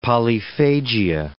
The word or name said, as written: polyphagia